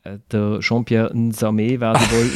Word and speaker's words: Jean-Pierre Nsame: Jean-Pierre Nsame. Jean-Pierre Nsame. Jean-Pierre Nsame.